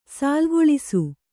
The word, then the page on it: ಸಾಲುಗೊಳಿಸು: sālugoḷisu